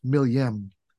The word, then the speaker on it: Milgem: So the Attaclass, the Milgem in English, I've always said Milgem because it's M-I-L-G-E-M. It's another acronym, but in Turkish, pronounced Milyem, is a very nice design.